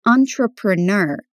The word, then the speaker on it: entrepreneur: It's entrepreneur, which means emprendedor. It's a word that comes from French, so the pronunciation isn't exactly like other English words.